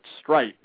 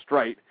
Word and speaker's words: Streit: I actually asked him up in Montreal 2 years ago, and he pronounces it Strite. So for all you Islander fans out there, it's actually Mark Strite, S-T-R-E-I-T.